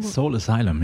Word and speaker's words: Soul Asylum: Soul Asylum, ja.